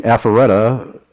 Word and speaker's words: Alpharetta: Oh, you know, I got an email, a follow-up email from Al, and he said that I am pronouncing Alpharetta very correctly.